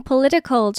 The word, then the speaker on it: political: Political，有没有发现 political 这个字它虽然有 t 这个字母，可是这个 t 它会念作 d 的音，变成 political。